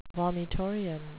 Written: vomitorium

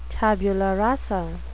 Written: tabula rasa

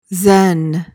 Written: zen

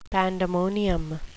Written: pandemonium

